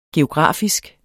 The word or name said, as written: geografisk